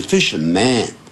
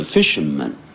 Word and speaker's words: fishermen: 它会保护这些渔夫，也就是捕鱼人，因为这是复数，就不要念成 fisherman，叫 fisherman 啊，这样子发耳钉。